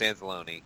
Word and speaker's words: Anzalone: I mean, Anzalone, you got Robertson, you got Klein, you got Te'o. – Is it really Anzalone and not Anzalone? – That's what he said. Yeah, it's Anzalone.